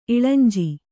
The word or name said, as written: ಇಳಂಜಿ